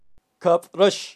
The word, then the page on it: kabrax: Kaqchikel